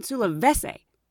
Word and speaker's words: Sulawesi: And I'm like, Sulawesi. And then one flight attendant said, oh, you mean Sulawesi? And I'm like, yeah, that's it, Sulawesi. So I started calling it Sulawesi because she's heard it pronounced before.